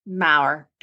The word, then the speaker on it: Maurer: It's like the first R. The first R is silent. Because a lot of people want to spell it Maurer.